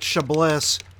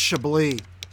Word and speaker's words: Chablis: I pronounced it Chablis. My brother called me a dumb little asshole. It's pronounced Chablis.